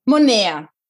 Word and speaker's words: moneda: A moeda, moneda, pronuncia, corta a última sílaba. Monea assim, é, é, é bem divertido.